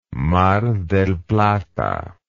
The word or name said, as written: mar del plata